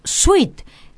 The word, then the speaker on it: suite: I com que ens sobren els diners, hem triat un bon hotel amb habitacions tipus suite. És una paraula que pronunciem sense la, sense E, elidim la E, però hem de tenir present que cal escriure-la.